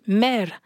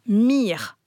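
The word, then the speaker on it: mr: ça se dit mer, ou alors mir.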